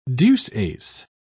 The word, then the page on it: deuce-ace: British English